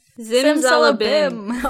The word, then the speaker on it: Zimzalabim: But another track that I really liked, just because I thought it was hilarious, was Red Velvet's Zim Zambaline. How do you pronounce it right? – Zimzalabim. – Oh, Zimzalabim.